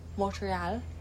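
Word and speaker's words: Montréal: in French, how we pronounce, because Quebec is a French province, so it's Montreal, yeah, tonight, Montreal.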